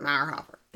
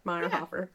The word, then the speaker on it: Meirhofer: Meyerhoffer. – That's how I think it's pronounced. – No, that's probably how it's said. But M-E-I-R. – Oh, yeah. – M-E-I-R-H-O-F-E-R. Meyerhoffer.